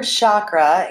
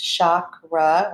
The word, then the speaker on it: chakra: The word chakra, it can be pronounced two different ways. Chakra or chakra, both are correct, means wheel or disc in Sanskrit.